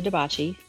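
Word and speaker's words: DiBachi: And I'm on LinkedIn, Rhonda DeBacci. Last name is spelled D like in David, I, B like in boy, A-C-H-I.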